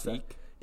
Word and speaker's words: Sikh: I'm an Indian, I don't really know how to pronounce it, S-I-K-H. I know it's a, how do you pronounce that? Do you know how to pronounce Sikh? – That? I think it's like Sikh. That, right?